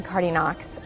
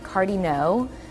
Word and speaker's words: Cardinaux: So Cardi No, which is spelled C-A-R-D-I-N-A-U-X, is French, and American kids always pronounce the silent X at the end, Cardi Knox.